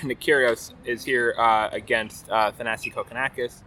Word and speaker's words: Thanasi: There's much more some discussion recently about how it's Thanasi, not Thanasi, people say apparently, but Thanasi is the way to go, based on his own pronunciation of it and how Nick says it as well.